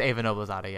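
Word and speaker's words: Eva Noblezada: I mean, Patrick Page, Amber Gray, Ava Noblezada. I always feel like I'm mispronouncing her name. – I think it's Ava Noblezada, yeah.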